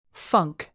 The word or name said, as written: funk